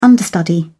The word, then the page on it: understudy: British English